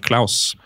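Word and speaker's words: Clauss: Så har jeg kjørt inn en eh, Hvordan blir uttalen der? En Jonathan Claus ja, ja.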